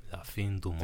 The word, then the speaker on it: La Fin du Monde: La Fin du Monde.